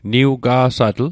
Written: niwiga'satl

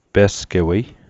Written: pe'sgewei

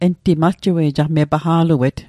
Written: paqa'luet

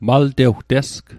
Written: maltewtesg

